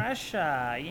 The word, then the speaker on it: Drescher: Drescher, yeah.